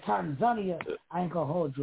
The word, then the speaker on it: Tanzania: Tanzania. – Americans say Tanzania. But they say Tanzania.